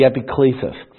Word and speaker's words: epiclesis: Another one is the Holy Supper, the Lord's Supper, signifies the work of the Spirit, the eleusis, the epilesis, I should say, exampled, and I'll give an example of just a church. The United Methodist Church really talks about this, the epiclesis. That's the exact pronunciation, by the way, the epiclesis.